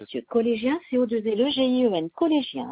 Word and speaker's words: Collégien: Collégien CEDEX, comme ça se prononce, collégien comme un collégien, CEDEX, CETEX.